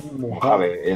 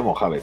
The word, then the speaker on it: Mojave: ¿cómo se pronuncia? – ¿Mojave? Mojave. En España, Mojave, tío.